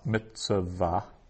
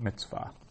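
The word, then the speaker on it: mitzvah (מצוה): So that's a two-syllable word instead of a three-syllable word. It's not mitzvah, but mitzvah.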